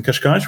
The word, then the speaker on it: Cascais: We're looking forward to our Congress, which happens June the 7th to the 9th in Cascais, which is just outside Lisbon in Portugal.